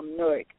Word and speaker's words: Newark: And you said it right. It's Newark. – Yeah, I did. – It sounds kind of like New York, but yeah.